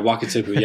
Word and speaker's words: Wakatipu: Lake Wakatipu. Just making sure Wakatipu. I say it right. Yeah, Wakatipu.